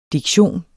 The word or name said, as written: DIKTIONERNES